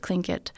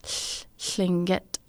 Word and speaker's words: Tlingit: Yeah, so you can pronounce it Tlingit, or the way we pronounce it is Tlingit. So it's, you put the tip of your tongue behind the top of the top of your mouth, behind your front teeth, and let air go through the sides of your tongue.